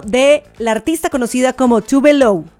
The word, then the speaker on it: Tove Lo: O sea, se llama Tove Lo, o sea, Tove Lo, es la pronunciación, pero su nombre real es Eva Tove Elsa Nilsson.